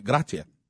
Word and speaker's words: grazie: You know, so because I was saying grazie, and they were like, "Yeah, you need to come up a little bit, grazie," and I'm like, "Oh, okay, that makes more sense."